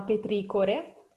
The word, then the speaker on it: petricore: Allora, la parola che vi insegnerò oggi si chiama petricore, che è con l'accento ah. sulla O e che è presente nella nostra pagina all'interno della rubrica Non si dice più così perché.